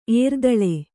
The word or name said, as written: ಏರ್ದಳೆ